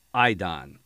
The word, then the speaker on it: eidon: It's eidon. It's E-I-D-O-N in Greek, and phonetically spelled is E-Y-E like I, and then hyphen D-O-N.